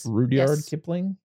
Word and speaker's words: Rudyard Kipling: He took a lot of these stories that he took from, as we know, this is based on a book by, is it Rudyard Kipling? I think that's how you pronounce his name. – Yes. – Rudyard Kipling. Yeah.